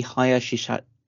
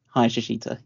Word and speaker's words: Hayashishita: So it's Itami Hayashishita. Hayashishita, yeah. Utami Hayashishita. And, so she was training with Natalia and Io Sky at the Performance Center as well.